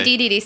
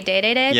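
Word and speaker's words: Dedede: Is it DDD? – I say DDD. Some people say Day-Day-Day, but I don't like that.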